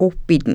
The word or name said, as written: wpitn